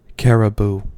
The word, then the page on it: caribou: American English